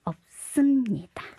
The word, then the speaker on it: 없습니다: 那这个思呢，修呢，它就移到后面去，可能发音就比较重一点的这个桑修的发音变成了 없습니다。